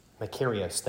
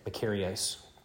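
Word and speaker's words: Makarios (μακάριος): The second is the one Matthew uses here in the Beatitudes, uh, uh, Makarios. Uh, oh man, I'm messing up that pronunciation. Makarios. Makarios, that's it. Makarios.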